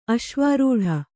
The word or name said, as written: ಅಶ್ವಾರೂಢ